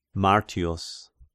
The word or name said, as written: Martius